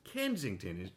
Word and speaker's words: Kensington: Kensington, am I pronouncing it correctly? – Yes, that's correct.